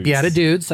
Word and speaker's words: Beatitudes: Beatitudes. – I said it right that time.